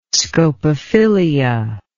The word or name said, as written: scopophilia